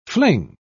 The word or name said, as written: fling